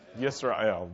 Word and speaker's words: Yisrael: How do you say that in Hebrew? Yisrael. That's right.